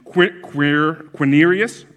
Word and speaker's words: Quirinius: This was the first registration when Quirinius, that's how you pronounce it, 100% sure, was the governor of Syria.